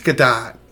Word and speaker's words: Gadot: But even beyond Fisher, you had Gal Gadot, who confirmed that Josh Whedon... – That was Gal Gadot. – You know, I thought that, too, and then I heard it was Gadot. So I think it's Gadot.